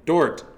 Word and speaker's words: Dort: Dort or Dork? – Dort. D-O-R-T.